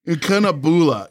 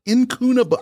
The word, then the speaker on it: Incunabula: Incunabula. Incunabula Press. Incunabula. I-N-C-U-N-A-B-U-L-A. – I would like you to keep trying for the next 10 minutes, please. – Here we go. How would you say Incunabula? – That sounds right to me.